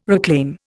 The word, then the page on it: reclaim: Indian